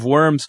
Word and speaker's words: Worms: worms? And then we'll maybe make our way to worms. – It took place in the city of I try to keep my German pronunciation Worms because, as I mentioned last week, I really did think as a kid they made him eat worms.